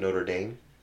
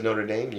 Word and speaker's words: Notre Dame: It's Notre Dame, not Notre Dame. So whoever says Notre Dame, you're wrong. It's Notre Dame. had an argument with that with somebody, and they're like, it's Notre Dame.